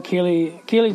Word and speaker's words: ceilidh: We did some Kaylee dancing. If you don't know what Kaylee is like a Scottish dance. It's what we call, or it's spelt like ceiling, which is CEILIDH.